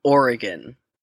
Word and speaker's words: Oregon: It's pronounced Oregon. Oregon. Oregon. Not Oregon. Oregon. Oregon. Oregon. It's very easy.